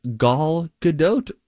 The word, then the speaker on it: Gal Gadot: And I heard them say that. I think Rock said it, actually. Gal Gadot.